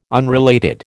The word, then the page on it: unrelated: American English